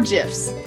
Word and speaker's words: GIF: GIFs or GIFs? I go back and forth my pronunciation. – GIFs is more fun because it's like peanut butter.